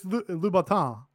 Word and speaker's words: Louboutin: I say gangrel, and I go, it's Louboutin. – Louboutin. Which is how you pronounce it.